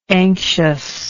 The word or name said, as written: anxiousness